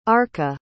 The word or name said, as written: arka